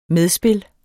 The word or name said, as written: medspil